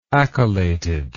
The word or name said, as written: accolated